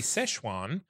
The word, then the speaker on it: Sichuan: I hope I'm not mispronouncing is Sichuan fried chicken. Now, I would suggest that it's usually Szechuan, but it's spelled S-I-C-H-U-A-N. So who knows? – Maybe they're rebranding.